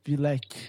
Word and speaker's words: Vlec: Vilec. Salve! Eu falei certo o teu nome? – Sim, sim.